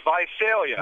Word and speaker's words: Visalia: Let's go next to this is Zazalia. Am I pronouncing that correctly? – It's Visalia. – Oh, Visalia in New York.